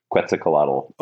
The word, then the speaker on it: Quetzalcoatl: First of all, did I pronounce Quetzalcoatl correctly? – I believe so. As a member of the San Jose community, I'm going to say yes, that is the correct way to pronounce Quetzalcoatl.